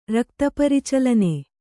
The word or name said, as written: ರಕ್ತಪರಿಚಲನೆ